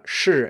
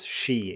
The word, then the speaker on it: shi: So shi in Mandarin is obviously not pronounced as she in English. It's a completely different vowel sound here, very different.